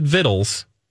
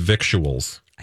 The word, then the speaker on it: victuals: Do you know that vittles is spelled victuals? – Oh. – Like, you pronounce it vittles. It's spelled victuals. You do not pronounce it victuals.